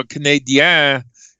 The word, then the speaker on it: Canadiens: We got the Maple Leaf games, the Buffalo Sabres games, and the Montreal Canadiens. That's how they pronounce it up there.